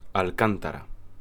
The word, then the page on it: Alcántara: Spanish